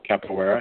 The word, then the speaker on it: capoeira: Capoeira? – Yeah. – I'm not. – Capoeira, okay.